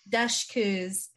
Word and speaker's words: Dushku: Dushku's yes, character reveal.